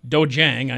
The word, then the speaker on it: dojang: And it is at your Dojang, I am told, is the correct pronunciation. – Yes, it is.